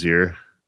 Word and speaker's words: hier: How do you say it? H-I-E-R. – Here. – It's just here.